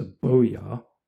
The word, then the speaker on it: boyar: The rank or title of boyar, which I think, by the way, is correctly pronounced as a boyar,